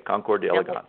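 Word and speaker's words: Concours d'Élégance: This is going to be, would you pronounce it for me, please? Because I never seem to get it right. The Concours d'Elegance. – D'Elegance, right. Concours d'Elegance.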